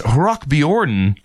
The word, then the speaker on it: Rockbjörnen: The song also earned him Sweden's Rockbjörnen Award for Song of the Year.